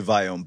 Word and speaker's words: Viome: I would say Viome, but don't feel bad.